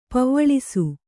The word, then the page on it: ಪೆವ್ವಳಿಸು: pevvaḷisu